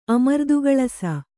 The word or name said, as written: ಅಮರ್ದುಗಳಸ